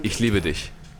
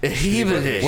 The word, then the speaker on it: Ich liebe dich: You Americans are morons. You cannot pronounce that shit. You always say, every American always says, ich liebe dich. They always say it with a sh.